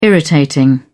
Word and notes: irritating: British English

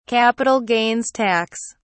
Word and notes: Capital gains tax: British English

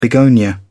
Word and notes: begonia: British English

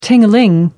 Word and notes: ding a ling: British English

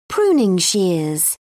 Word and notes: pruning shears: British English

